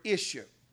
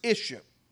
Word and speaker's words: ishshah: This word woman out of the Hebrew is the word Isha. Praise God. Sound like I-S-S-U-E, but it's actually I-S-S-H-A or something to that effect. Yeah, I-S-H-S-H-A-H. It's a Hebrew word. I-S-H-S-H-A-H. Praise God is the word. It's pronounced Isha.